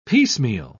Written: piecemeal

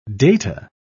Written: data

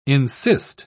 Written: insist

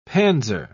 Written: panzer

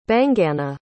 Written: baingana

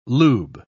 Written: lube

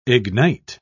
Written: ignite

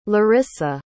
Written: laryssa

